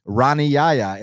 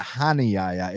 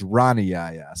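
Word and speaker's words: Rani Yahya: So, and interestingly enough, Rani Yaya, it's not Hani Yaya, it's Rani Yaya.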